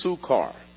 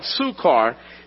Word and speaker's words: Sychar: So he came to a town of Samaria called Sukkar I know it's pronounced, I mean, it's spelled S Y C H A R, but it's pronounced Sukar. So he came to the town of Samaria called Sukar, near the field that Jacob had given to his son Joseph.